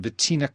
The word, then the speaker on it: Vitina: There's a story you tell in the introduction to the book about walking down the street in Vitina, Kosovo.